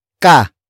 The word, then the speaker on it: ka: The next word is ka.